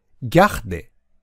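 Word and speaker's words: garder: 後ろの二つ、ギャ、でだけをくっつけてギャッデ、ギャッデと発音します。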